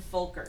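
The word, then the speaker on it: Völker: Engel and Folker. It's pronounced as an F. – German. – You say it quickly, like Folker.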